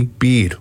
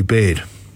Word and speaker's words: Beard: Frank Lee Beard, or Frankly Baird, depends where you went to school. It's spelt Beard.